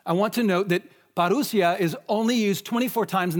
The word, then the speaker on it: Parousia: It's pronounced, or it's spelled P-A-R-O-U-S-I-A. Parousia.